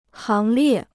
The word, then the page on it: 行列: háng liè